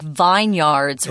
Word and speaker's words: vineyard: Yard isn't even pronounced correctly. So vineyard, be careful with that one.